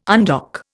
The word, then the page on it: undock: Indian